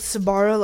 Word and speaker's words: Sabara: How do you say his last name? – I said Sabara, like the pizza place.